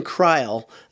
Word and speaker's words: Kreul: Another guy that I think could be the newcomer of the year is a true freshman named Ben Kreil. It's spelled, it looks like Kreul, K-R-E-U-L.